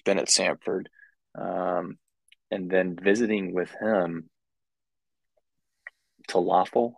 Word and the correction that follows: Taofele: Taofele. That's right. Taofele.